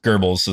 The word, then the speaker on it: Goebbels: So basically, Joseph Goebbels, is it Goebbels? – Goebbels is how you pronounce it.